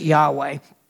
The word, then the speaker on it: Yahweh: It's actually in Hebrew, Y H W H. We pronounce it Yahweh.